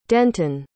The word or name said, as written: denton